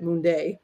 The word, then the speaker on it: Monday: One of which, I'll tell you, John Monde, his name is spelled Monday, but he said it Munde.